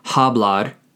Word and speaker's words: hablar: Hablar, spelled H-A-B-L-A-R. Keep in mind the H at the beginning of the word is silent, so it's not pronounced hablar, it's pronounced hablar.